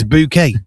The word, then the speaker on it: Bucket: That's B-U-C-K-E-T. Oh, bucket. No, it's bouquet, but it's spelled bucket.